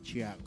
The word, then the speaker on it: Tiago: Se escribe Tiago, se pronuncia Chiago.